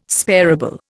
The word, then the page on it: sparable: Indian